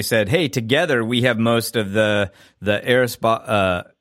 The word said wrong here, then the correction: Aeropostale: I always say it wrong, do you? Aeropostale, sorry.